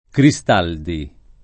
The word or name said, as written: Cristaldi